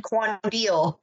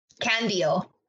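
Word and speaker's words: Qandeel: Number one, I had to listen to a video on how to pronounce it, and I sat here pronouncing it because I see a Q, and I automatically think the wrong sound. – What sound do you think when you see a Q? – Qua. – Okay, yeah, fair. English. – So I would have said Quandil, and I was like, nope. – The formal pronunciation is? – Candil. – Okay. – Like a K sound.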